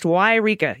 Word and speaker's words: Yreka: Yreka is pronounced Yreka. Eureka is a totally different town, also in Northern California, but a few hundred miles towards the coast.